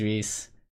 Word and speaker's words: Rhys: You're right, his name is Reese. Yeah, Reese, dude, and Fiona. – But it's not spelled like normal Reese, it's all this... You know, R-H-Y-S.